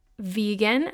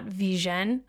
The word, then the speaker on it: vegan: And so 3 months after coining the term, he actually issued a formal explanation of the way the word should be pronounced, which is vegan as we know it, not vegan.